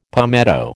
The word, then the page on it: palmetto: American English